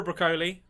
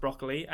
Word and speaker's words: Broccoli: So, first off, Barbara Broccoli, and it's not pronounced broccoli, and you have no idea how hard it is for me not to pronounce her surname as broccoli, because Barbara Broccoli sounds like a character in a children's TV show made in the 50s.